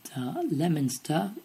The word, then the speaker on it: Leominster: First one is spelt L-E-O-M-I-N-S-T-E-R. If you said Leominster, then... If you said Leominster... Leominster is the correct answer. Let's try the next one. L-E-I-C-E-S-T-E-R. L-E-I-C-E-S-T-E-R.